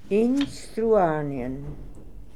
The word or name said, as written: Instruarnian